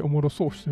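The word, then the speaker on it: おもろさうし: おもろそうしと読むんですけども、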